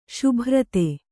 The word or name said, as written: ಸಭರಿತ